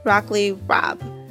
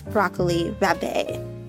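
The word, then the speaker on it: broccoli rabe: I was the wealthy aunt, Miss Broccoli Rabé, but it's actually pronounced Broccoli Rob, like rob a bank.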